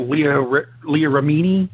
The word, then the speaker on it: Remini: Still trying to figure out Leah Remini. Is that how you pronounce her last name? – That's how I pronounce her last name.